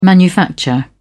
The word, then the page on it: manufacture: British English